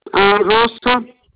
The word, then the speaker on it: Rosa: Rosa. – Can you spell it for me? – R-O-S-A.